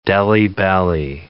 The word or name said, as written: Delhi belly